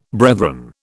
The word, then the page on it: brethren: British English